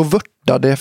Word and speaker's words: vörta: Men var det då vörtade fem. – Där av namnet vörta. V-U-R-T-A. – Vörta. – Alltså, det måste uttalas så.